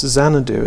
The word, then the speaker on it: Xanadu: But of course, the way we pronounce Xanadu as well, it's Z. So you've got A to Z looping through this poem.